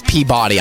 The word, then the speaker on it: Peabody: No, that's Peabody, I'm sorry. – Peabody? That's how you say it? Peabody.